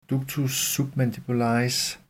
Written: ductus submandibularis

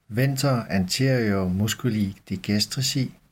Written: venter anterior m. digastrici

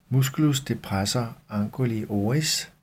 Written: m. depressor anguli oris

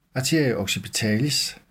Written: a. occipitalis

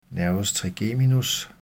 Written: n. trigeminus

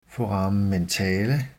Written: foramen mentale

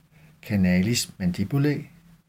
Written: canalis mandibulae

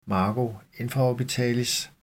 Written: margo infraorbitalis

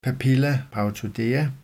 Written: papilla parotidea

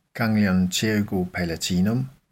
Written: ggl. pterygopalatinum

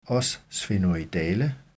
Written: os sphenoidale

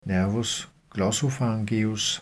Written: n. glossopharyngeus

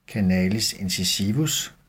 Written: canalis incisivus

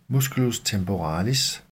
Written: m. temporalis

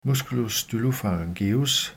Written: m. stylopharyngeus

